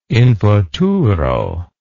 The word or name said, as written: infuturo